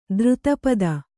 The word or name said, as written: ತೀರ್ಥಪಾದ